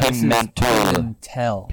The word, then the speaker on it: Pimentel: Pimentel. Are you familiar with the cheese? – Pimento. – Pimento with an E-L at the end, am I right? – Pimentel. Pimentel. – P-I-M-E-N-T-E-L. – Pimentel.